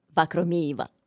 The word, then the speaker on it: Vakhromeeva: Vakhromeeva.